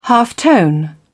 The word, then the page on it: halftone: British English